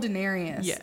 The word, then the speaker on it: Daenerys: So they killed Daenerys in the end. – Is that how you say it? – Yeah. – Oh, I've been saying that wrong in my head for years. – Yeah, Daenerys Targaryen.